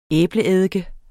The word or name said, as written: æbleeddike